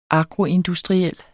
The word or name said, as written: agroindustriel